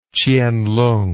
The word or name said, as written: chien lung